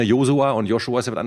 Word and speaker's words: Josua: PS, eine biblische Person heißt Josua. Bei ihm spricht man das S nicht als Sch aus. Ich denke, das ist viel klüger, als es wie Joshua auszusprechen und ich würde mich über die weitere Nutzung des Namens Josua freuen.